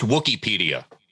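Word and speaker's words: Wookieepedia: And it's pronounced Wookiepedia.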